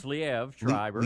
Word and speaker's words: Liev Schreiber: Liev Schreiber? Schreiber? Is that how you say his name? Liev Schreiber? – It's Liev Schreiber.